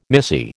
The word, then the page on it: missy: American English